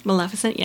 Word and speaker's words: Maleficent: Maleficent, yes.